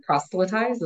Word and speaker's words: proselytize: So even though Launch has kind of that foundation in wanting to walk like Jesus, because in that fashion, they don't, I'm going to mispronounce the word, proselytize. Is that the way you say it? Yep, nailed it.